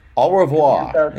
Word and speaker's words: au revoir: Au revoir. Yeah. Just, you know. It's French. You leave off the last few letters.